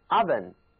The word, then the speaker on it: oven: 而这个 oven 因为呢它是一个炉子、一个烤箱，用来烤东西的，所以啊应该把口敞开了，能够把东西塞进去，自然呢这个字母 O 它就应该读作大张着嘴的三角啊，也就是 oven, even and oven。